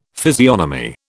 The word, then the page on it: physiognomy: British English